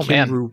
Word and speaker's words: Cymru: Oh, Cymru, man. – Yeah, Cymru, Wales. The word we're looking at is spelled C-Y-M-R-U. I'm sure it has some bizarre pronunciation that I've never heard of.